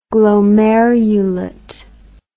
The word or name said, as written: glomerulate